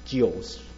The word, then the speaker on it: Zeus: 啊，Zeus，如果到希腊文，应该读作 Zeus。